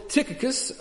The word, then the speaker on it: Tychicus: And so Tychicus, and just for a record, I do know that's how you pronounce that name because I looked it up and I had it read it to me. So I do know that's how you say that name in Greek.